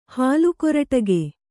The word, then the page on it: ಹಾಲ್ಕೊರಟಿಗೆ: hālkoraṭige